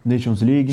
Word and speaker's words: Nations League: Já começa a como é que fala? Nation League, né? – Nations League.